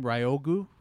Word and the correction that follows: Ryugu: Ryugu uh, orbits the sun at a distance of 0.96 to 1.41 AU, which is astronomical unit.